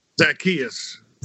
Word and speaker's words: Zacchaeus: I read the recent column that you wrote about Zacchaeus. Is that right? Zacchaeus Golden, the chef? – Yeah. Zacchaeus. – Zacchaeus Golden. Yeah. I wasn't sure how to pronounce it, but he goes column, by Zacchaeus, yeah.